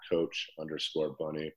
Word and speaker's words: Bunny: It's at coach_bunny. Bunny is spelled B-U-N-N-Y.